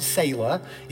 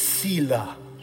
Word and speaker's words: Selah: Emphasis on the, when you pronounce it, don't pronounce it sailor. It's Selah.